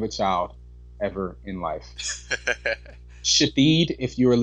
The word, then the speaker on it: Shithead: S-H-I-T-H-E-A-D pronounced Shahid. One more time. That's S-H-I-T-H-E-A-D. And let me just say that I saw this firsthand. And the mother had the nerve to get an attitude when someone made a mistake and actually pronounced it the way that they read it. Just think about that. She corrected them as if they should have known that it was pronounced Shahid.